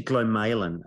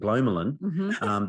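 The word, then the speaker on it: glomalin: And it's actually Glamalan, apparently, is how it's supposed to be pronounced. And I had no idea how it'd be pronounced, because we say in ICU call it Glomalan, because we'd only ever read it and never heard it talk about it.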